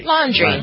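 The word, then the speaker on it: Laundrie: I don't want to say that, but that is how you pronounce his name, right? Laundry, like doing the wash.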